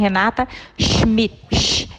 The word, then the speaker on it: Renata Schmidt: É, primeiro, eu quero só corrigir. o meu nome é um pouquinho, a pronúncia é um pouquinho outra, porque não é americana, é alemã. Então, meu nome é Renata Schmidt.